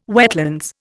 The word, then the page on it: wetlands: Indian